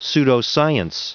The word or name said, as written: pseudoscience